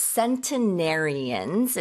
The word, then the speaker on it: centenarian: It's centenarians. It's spelled C-E-N-T-E-N-A-R-I-A-N. – Centenarian, like century, Like century, yes.